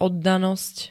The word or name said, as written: oddanosť